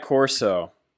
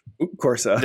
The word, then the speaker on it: Corsa: This was about Assetto Corso. – Corsa.